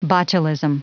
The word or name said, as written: botulism